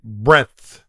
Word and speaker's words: breadth: It's market breadth. – Breadth. – Breadth. – Breadth. – Right, not breath. Not like breathing. Breadth.